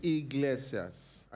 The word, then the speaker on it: Iglesias: You say his name is Eyeglass. Eyeglass, can he like Anna? – Enrique Iglesias, I said.